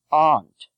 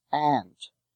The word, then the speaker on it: aunt: In New England, it is pronounced aunt. Elsewhere, it is pronounced with a short A as ant.